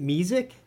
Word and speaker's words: mesic: I always pronounced it mesic. Is the right way of saying it mesic? – I say mesic too, so.